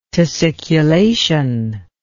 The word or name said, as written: tussiculation